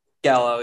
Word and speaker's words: giallo: He said that it's inspired by, I'm going to pronounce it wrong, Gallo Films, G-I-A-L-L-O. – Gallo, yeah.